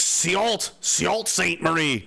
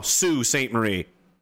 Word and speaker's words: Sault Ste. Marie: It looks like Sault Ste. Marie. No, Sault Ste. Marie. Why? No idea. How did S-A-L-U-T, whatever, become Sue?